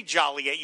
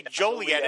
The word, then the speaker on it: Joliet: You don't say Joliet. You say Joliet. Yeah. And only people from Joliet would know to say it correctly.